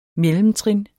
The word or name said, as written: mellemtrin